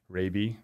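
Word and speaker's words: Raabe: And as we were talking before, getting my pronunciation, my last name correct, Raby, oftentimes confused with many other pronunciations besides that.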